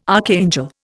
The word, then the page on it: archangel: Indian